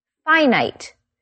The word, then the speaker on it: finite: Although the pronunciation is a bit different. Finite.